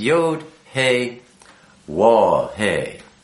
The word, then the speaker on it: Waw: A lot of people like to say Vav instead of Waw, but the pronunciation of the V or the letter V came much later in time. Originally, it's the or more ancient is the W.